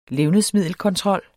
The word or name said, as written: levnedsmiddelkontrol